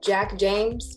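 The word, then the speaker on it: Jvck James: His name is Jack James, but it's spelled J-V-C-K James.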